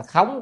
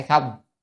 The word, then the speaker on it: khống: cái chữ này phải đọc là khống chứ không phải không à, phải đọc là khống kỳ cảnh giới,